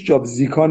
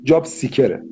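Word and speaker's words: Job Seeker: خب جاب زیکا چقدر طول میکشه آقای دکتر الان با توجه به شرایط کرونا؟ – مهندس تلفظ درستش جاب زیکا نیست، جاب سیکره. سیکر یعنی جستجو کننده.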